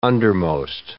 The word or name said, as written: undermost